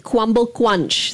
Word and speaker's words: Kwumble Crunch: Why spell it and say it that way? – It's just the way. Luqman, can you say it? – Say it, Luqman. – Just say the word Kwumble Crunch. – Sorry, what? – Say the word crumble crunch. – Say crumble crunch. – Crumble crunch. – The way he pronounces it. – I can't pronounce the R letter.